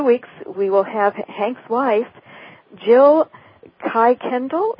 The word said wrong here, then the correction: Kirkendall: Is that how you say it? – Well, she says Kirkendall.